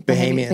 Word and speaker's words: Bahamian: And when did you meet your beautiful, how do you say it, Bohemian? – Bahamian. – Bahamian, yeah. Wife.